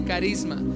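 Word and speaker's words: carisma (χάρισμα): se escribe con CH, charisma, se pronuncia carisma.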